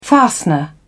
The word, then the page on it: fastener: British English